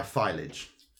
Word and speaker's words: Filage: Filage. – Rhyming with silage.